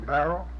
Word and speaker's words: barrow: Okay, could you pronounce that for me? – A barrel? – Yeah, uh-huh. B-A-R-R-O-W. – Yeah. Barrel. – Okay. – Like a wheelbarrow. not exactly the same, but...